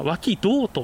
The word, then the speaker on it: 脇道: はい、実はですね、この殿木戸周辺ですね、あの南側にですね、脇道遺跡という、まあ、脇にそれぞれの脇に道と書いて、はい、まあ、脇道と読むんですけども、